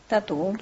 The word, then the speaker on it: Tatú: Tatú. – Sí, con acento en la U, pero es un juego de palabras, en realidad es el tatuaje.